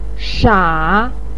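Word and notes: sha: tone 3

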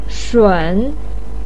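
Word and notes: shun: tone 3

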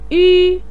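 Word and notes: yu: tone 1

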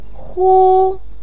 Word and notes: hu: 1

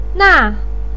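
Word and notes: na: tone 4

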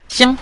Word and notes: xiang: xiang5

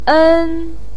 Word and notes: en1: pinyin

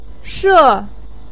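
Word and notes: she: tone 4

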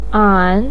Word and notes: an: an3

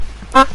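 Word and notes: da: tone 5

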